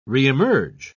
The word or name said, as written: reemerge